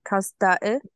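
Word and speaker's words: Kaz Dağı: And in Turkey it's called Kasda, which means I think I pronounced that wrong. Kasda-ı. – You're right, yep.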